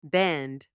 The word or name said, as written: bend